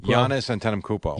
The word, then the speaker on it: Giannis Antetokounmpo: This Giannis Antetokounmpo. – Giannis Antetokounmpo.